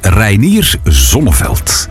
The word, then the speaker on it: Reinier Zonneveld: wacht, je moet dat zo uitspreken. Hè? Reinier Zonneveld. – Het is inderdaad een man uit Nederland. Ah. Dus dan zou het belachelijk zijn om dat anders te zeggen.